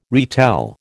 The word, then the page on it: retell: American English